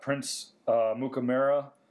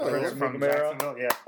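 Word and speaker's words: Amukamara: We also got Prince Mukamara. That's how you say his name, yep. – I think. – No, that's perfect. – Prince Mukamara.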